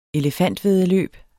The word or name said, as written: elefantvæddeløb